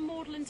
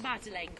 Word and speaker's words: Magdalen: May the 1st, at the top of Magdalen Tower, Magdalen, not Madeleine College. It's the pronunciation is medieval. That's why we say Magdalen, not Madeleine. There's a tower, and there's a school connected to this, the college.